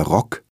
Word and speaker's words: Rock: Aber wenn nach dem O ein CK oder ein Doppelkonsonant folgt, dann spricht man es kurz aus. – Der Rock.